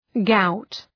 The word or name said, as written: gout